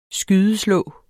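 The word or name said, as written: skydeslå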